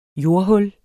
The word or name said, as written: jordhul